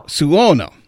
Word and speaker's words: Suono: And this is Diva Pavalaguna, which I think is a very interesting name, sings, and hopefully I'm pronouncing this correctly because I'm not Italian, El Dolce Suono. – Suono. – There we go.